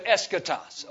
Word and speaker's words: eschatos: And phonetically, it'd be spelled like that, E-S-C-H-A-T-O-S, eschatos.